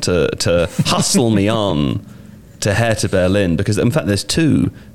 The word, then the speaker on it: Hertha Berlin: Seb, the first one, Hertha Berlin.